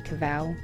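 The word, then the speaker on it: Covell: I've got social media platforms on Facebook and Instagram, and then my website, and it's just Maggie Cavell, tricky surname, that's C-O-V-E-L-L.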